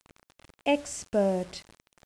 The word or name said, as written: expert